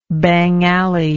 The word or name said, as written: bangalay